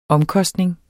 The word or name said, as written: omkostning